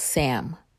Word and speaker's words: Siem: And our tour guide was Sim, or pronounced Sam, S-I-E-M.